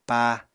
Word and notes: pá: Apatani

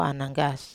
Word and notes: ananggas: Wamesa

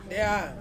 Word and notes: dea: Ansus

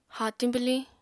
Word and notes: haatiñ bwliñ: Apatani

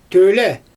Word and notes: tøjle: Xyzyl

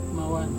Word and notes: mawan: Serewen

